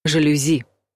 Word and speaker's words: жалюзи: Слово французское, поэтому ударение падает вновь на «и» — «жалюзи».